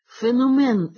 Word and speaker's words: феномен: Втім, таке доводиться чути не раз, хоч за нормами вимови слово «феномен» треба наголошувати на другому складі.